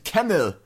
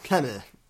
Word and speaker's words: Camel: Und wir hatten ja lange diskutiert, wie denn Agent Camel ausgesprochen wird. Da hatten wir Camel, Kiameru, was auch immer. Jetzt wissen wir, es ist Agent Camel. – Ja, Camel. – So wird er ausgesprochen.